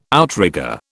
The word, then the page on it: outrigger: British English